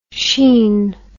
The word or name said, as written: Sheene